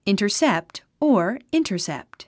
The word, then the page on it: intercept: ìntərsépt; インタァセェプト